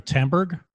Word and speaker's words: Tamberg: I'm sorry, Tamberg? How do you spell that? – Tamberg, T-A-M-B-E-R-G. Eino, E-I-N-O, Tamberg.